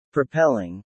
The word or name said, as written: propelling